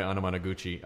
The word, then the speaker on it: Anamanaguchi: Anamanaguchi. They're amazing. – Don't confuse it with Onomatopoeia. – That's exactly. I asked them how to pronounce their name, and they said it's like Onomatopoeia.